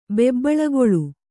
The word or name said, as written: ಬೆಬ್ಬಳುಗೊಳ್ಳು